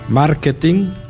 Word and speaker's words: marketing: Y así hemos de escribir marketing, casting, camping, catering, sin la G, aunque también pueden escribirse con ella. Pero en la pronunciación nunca debe sonar la letra G.